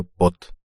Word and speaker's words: Пот: Этими фруктами Джейк Мэддиган сражался потом с игровым автоматом, а в итоге расстался с рубашкой. Очень свободного мышления человек. Ее звали Флоринда Пот. Сама она произносила свою фамилию якобы фламандского происхождения как По, белобрысая и вся веснушчатая, как говорится, от носа до кормы.